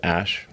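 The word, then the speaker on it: Æ: So, I mean, it's just X, the letter X, and then the AE is, like, pronounced Ash. yeah.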